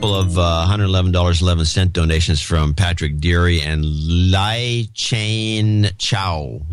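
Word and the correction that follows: Chyn: Lai Chan. – C-H-Y-N.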